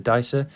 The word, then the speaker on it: DISA: The Red DISA Consortium, I think it's pronounced DISA, DISA, D-I-S-A.